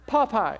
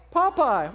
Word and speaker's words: Popeye: But anyway, you'll be ready to fight Pi Pi when he eats the spinach, that is, okay? Did I say it wrong? What did I say? Popeye. Is that better? Popeye.